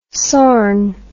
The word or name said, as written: sorn